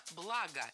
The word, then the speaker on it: блага: Блага, неверно, правильно, благо. Перед нами, опять же, слово с неподвижным ударением на основе. Не забываем об этом и правильно склоняем. Благо, благом, благами, благах.